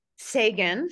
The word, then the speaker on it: Seguin: Joining me today on this amazing episode is number one international bestselling author, Michelle Saigon. Am I saying that right? Sagan. Sagan, here I go, learning radical recovery of self coach and the heal your life teacher and coach.